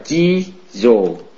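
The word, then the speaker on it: Chi Dồ (地蔵): Còn Địa Tạng á, thì người Nhật họ đọc là Chi Dồ Địa Tạng.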